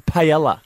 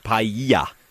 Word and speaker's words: paella: which I tell you now is called paella. – Paella.